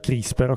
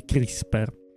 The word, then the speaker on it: CRISPR: Questa qua si pronuncia CRISPR, ok? Quindi CRISPR.